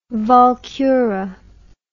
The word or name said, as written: walkure